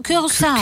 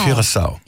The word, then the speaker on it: Curaçao: Det Curaçao, tror jeg, det udtales. Curaçao. – Curaçao.